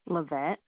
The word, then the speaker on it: Lavette: Okay, so Fabric, F-A-B-R-I-C, and Memories, M-E-M-O-R-I-E-S? – Yes, L-O-V-E-Y. – Oh, okay. What's your first name, ma'am? – Lavette. – Could you spell it for me? – L-A-V-E-T-T-E.